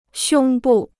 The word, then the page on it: 胸部: xiōng bù